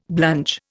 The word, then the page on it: blunge: Indian